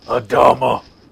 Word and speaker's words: Adama: Is that am I saying that right? – My ship. Adama? Adama. Yes. – Now you can said it right.